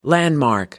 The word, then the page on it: landmark: American English